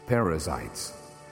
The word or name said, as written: Perizzites